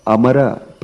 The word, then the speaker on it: Amara: And there came a particular shloka there, which says, Padmanabha Amara Prabhu. Amara means demigods, devas. So, it says that Padmanabha Amara Prabhu. But Pundanam was pronouncing it as Padmanabha Mara Prabhu.